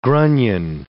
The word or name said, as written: grunion